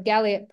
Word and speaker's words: Galliot: And she's also the co-founder of Galliot Press.